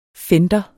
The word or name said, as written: fender